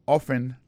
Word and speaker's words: often: Often times. Often times nan, se O-F-T-E-N, times, T-I-M-E-S, alors c'est comme ça qu'on dit ça. So, on dit often, often, non, c'est O-F-T-E-N, malgré l'écrit often, on prononce often.